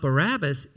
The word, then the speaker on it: Barabbas: In fact, pronouncing his name Bar Abbas highlights the name's meaning.